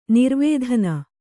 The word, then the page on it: ನಿರ್ವೇಧನ: nirvēdhana